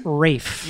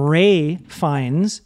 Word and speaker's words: Ralph: Written Ralph. But it's pronounced Rafe. – Yeah. – But you know... – It sounds like people are calling him Ray Fiennes, but it's Rafe Fiennes.